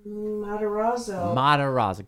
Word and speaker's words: Matarazzo: Matarazzo.